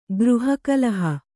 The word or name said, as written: ಗೃಹಕಲಹ